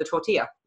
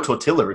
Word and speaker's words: tortilla: And that happens to be the tortilla. – Mm. Or tortilla if you want to be a gringo. – Yes. We're learning Spanish right now. We know the double L is pronounced a y.